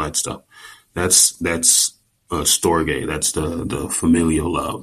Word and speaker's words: storge: Um, the next is storge. Now it, it, it's spelled like store J like S T O R G E, but it's pronounced Storge.